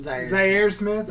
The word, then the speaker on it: Zaire: What they did is they signed, how do you say his first name? Zaire Smith? – Zaire Smith.